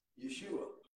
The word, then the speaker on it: Yeshua: Yeshua is pronounced with a Y. Yeshua. Mm-hmm. There is no J in the Hebrew alphabet.